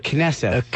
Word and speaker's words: Knesset: And then the eighth night, Tuesday, December 19th, 6 p.m., the Ellington Congregational Knesset, is that how you say it? – Knesset. – Knesset, congregation.